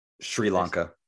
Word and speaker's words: Sri Lanka: Well, as of right now, I think it's only, was it Sierra Lavanka? Is that how you say it? – Sri Lanka.